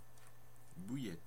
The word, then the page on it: bouillette: France (Normandie); /bu.jɛt/